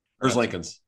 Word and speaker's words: Merzlikins: Also have the goaltender Elvis Merzlikins. I can never get his Merzlikins. Elvis. – I did say it right.